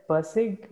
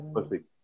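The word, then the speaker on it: Pirsig: Persig. – Persig.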